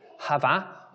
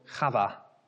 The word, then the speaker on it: Chava: Well, Eve in Hebrew is translated, get my pronunciation right here, Hava or Chava, which translates as life.